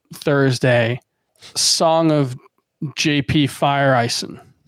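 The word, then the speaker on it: Feyereisen: and it literally sounded like Fire Ice End.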